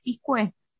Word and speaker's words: Izcue: ¿Es Iscue? – Perfecto, lo dijiste ah, perfecto.